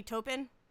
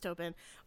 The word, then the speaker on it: Taupin: Yeah, so I think it's Bernie, and I think it's pronounced Taupin.